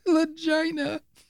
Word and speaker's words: Lagina: I call it L-A-G-I-N-A. That's Legina. – Legina. – How do you pronounce it? – Legina. – Legina is Legina. You're wrong. The one time I'm going to correct you on a name.